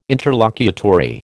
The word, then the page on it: interlocutory: American English